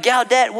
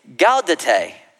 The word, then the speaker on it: Gaudete: Because the proper pronunciation, where's my high church people, is what? Okay, there's nobody in here. It's Methodist, Episcopalian, or Presbyterian. Okay, it's Gaudete. Three syllables, not two.